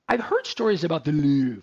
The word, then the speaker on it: Louvre: The Louvre. I just like how to say the Louvre. L-O-U-V-R-E.